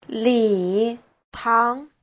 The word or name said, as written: li3tang2